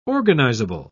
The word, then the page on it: organizable: British English